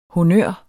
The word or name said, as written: honnør